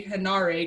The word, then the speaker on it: Henare: So I've been slowly teaching myself to say Henare because that's how it's spelled.